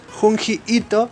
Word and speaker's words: Junji Ito: Y lo que tiene este autor, que se llama Junji Ito, se escribe Junji con J, las dos, Junji Ito.